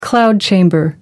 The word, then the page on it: cloud chamber: American English